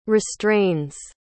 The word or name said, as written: restrains